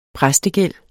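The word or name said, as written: præstegæld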